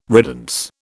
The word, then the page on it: riddance: British English